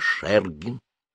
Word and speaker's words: Шергин: А другие, к стыду нашему, не знали, не читали и даже не слыхали этого славного имени, а если слыхали фамилию, то произносили ее неправильно, правильно Шергин.